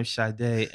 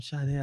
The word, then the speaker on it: Sade: It was like, hi, I'm Sade.